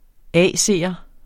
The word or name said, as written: AC'er